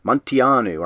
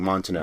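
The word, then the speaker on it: Munteanu: The, what is it, Romanian pronunciation you found? – Montianu. – So I'm hearing Montianu, but Montianu. – Yeah, I hear it more like Montanu. – But we were saying Montanu. – Montanu. – And that's that was definitely not right.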